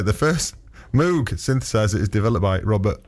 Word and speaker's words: Moog: How do you say it then? – Moog. – Oh. Why not M-O-G-E then? That would make more sense. – Because that's his name.